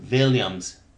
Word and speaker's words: Williams: Yes, Williams.